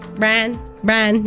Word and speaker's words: Bryan: Bryan, yeah, Luke Bryan. – It's B-R-I-N-E. – Luke Bryan. – The way he pronounces it is Bryan. – It's like pickle.